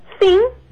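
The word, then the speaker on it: sim: ¿Cómo se dice sí? – Sin. Sin. – Sin, con N al final.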